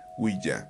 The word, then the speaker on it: Ouija: El significado del nombre proviene del francés oui, que significa sí, y del alemán ja, que también significa sí. Pronunciado suena Ouija.